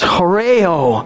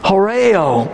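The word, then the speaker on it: horeo (ὁράω): Now, we have it in the English, we can read it, but it's a Greek word that is pronounced horeo, horeo.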